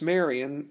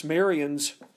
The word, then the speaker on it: Marion: Interesting enough, Francis Marion's family, being that they were French Huguenots, their last name was originally pronounced Marion. But of course, when they came to America, it was anglicized and we get Marion. Well, it turns out that Revere in French was pronounced Rivoire, R-I-V-O-I-R-E, Rivoire.